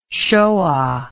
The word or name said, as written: shoa